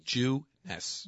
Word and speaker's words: Jeunesse: All you have to do, it's J-E-U-N-E-S-S-E, but it's pronounced J-E-W-N-E-S-S. – Okay. – Jeunesse.